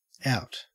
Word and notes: out: Australian English

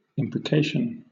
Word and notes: imprecation: British English

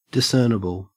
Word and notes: discernible: Australian English

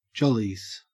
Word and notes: jollies: Australian English

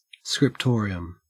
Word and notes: scriptorium: Australian English

